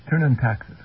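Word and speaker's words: Thurn und Taxis: Did I pronounce that right? Taxis. Oh, sorry, I missed that. – Turnund Taxis. – Turnund Taxis. 12 votes.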